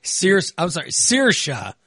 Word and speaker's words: Saoirse: And Sirsa, whatever, is that how you pronounce it? – Saoirse.